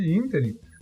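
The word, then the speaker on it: ínterim: E é claro que nesse interim, nesse interim, como diz a minha, minha namorada, ela me ensinou que na verdade eu pronunciava isso errado, nesse interim, Uh,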